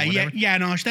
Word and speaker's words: Janosch: I can't even say his name, like Janox or Janzak. – Yeah, Janosch. That's like Janosch, his real name.